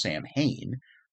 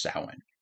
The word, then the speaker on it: Samhain: Now, it's spelled Samhain. Looks like you'd say it as Samhain, but it's actually pronounced Samhain.